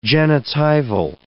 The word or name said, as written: genitival